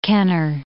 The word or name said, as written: Kenner